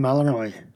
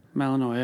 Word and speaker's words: Malinois: So the dog is a Belgian Malinois. – Malinois, yeah.